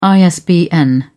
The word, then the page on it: isbn: British English